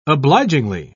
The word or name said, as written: obligingly